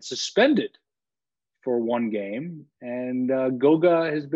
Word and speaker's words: Goga: and correct me on this pronunciation, Gogo Badazzi. Am I saying that correctly? Let's call him Goga.